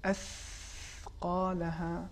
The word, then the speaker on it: اثقالہا: دیکھیں لفظ اثقالہا، اس کا صحیح تلفظ یہ ہے اثقالہا، یہ مثال کے طور پر حرف ہے.